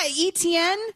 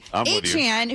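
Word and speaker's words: Achane: And so apparently, A-Chain, the artist formerly known as ETN, says that it's always actually been A-Chain, but we've always been pronouncing it wrong with ETN.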